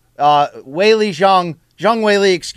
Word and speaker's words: Zhang Weili: Zhang Weili, is the correct way to say that.